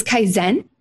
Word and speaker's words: Kaizen: It's Kaizen. – Kaizen. – Okay. Very close. Uh, um, very common though pr- to pronounce it Kaizen.